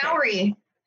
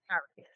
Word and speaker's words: Mowry: So, Sister Sister is an American television sitcom starring identical twin sisters, Tia and Tamara Mowry. I hope I'm saying that right. I'm probably not, but... – No, you are! It's Mowry. Mowry.